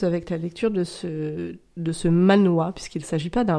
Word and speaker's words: manhwa: D'accord, on dit manhwa. – Alors, je ne sais pas si c'est comme ça que ça se dit, mais en tout cas, M-A-N-W-H-A. D'accord. Je prononce Manois.